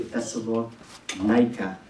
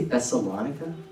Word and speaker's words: Thessalonica: And now they're off to Thessalonica. I always say Thessalonica, but I think it's pronounced Thessalonica.